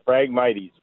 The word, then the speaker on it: phragmites: My question is I've got some of that phragmite. What is it, phragmite? Am I pronouncing that right? In my back kind of wetland area. – Yeah, phragmites.